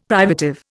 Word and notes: privative: Indian

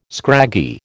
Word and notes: scraggy: American English

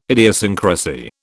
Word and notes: idiosyncrasy: British English

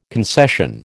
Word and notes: concession: American English

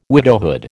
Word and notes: widowhood: American English

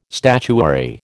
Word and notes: statuary: American English

